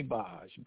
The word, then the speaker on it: Baj: I spell that M-B-A-J-I. My friends call me Baj, B-A-J. – Baj.